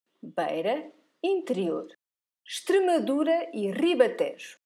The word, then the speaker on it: Extremadura: So you don't pronounce the I, you say Extremadura.